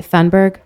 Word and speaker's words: Thunberg: And I think, you know, examples like Greta Thunberg, am I saying her name right? Thunberg? – Right.